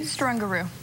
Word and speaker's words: Strungaru: Strungaru. – Spell it for me. – S-T-R-U-N. G-A-R-U. It's pronounced like kangaroo.